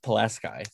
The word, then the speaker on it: Pulaski: I didn't know people knew Kazimierz Pulaski outside of like Poland and Illinois, but we have a town called Pulaski here, but it's pronounced Pulaski. Thank you. Because we're dumb.